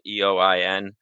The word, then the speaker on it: Eoin: My name's Eoin Rogers. My first name's a little tricky. It is spelled Eoin.